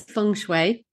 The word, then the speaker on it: feng shui: So how I say it is feng shui. So I think you actually did a really good job.